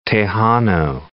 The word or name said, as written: Tejano